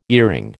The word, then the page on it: earring: American English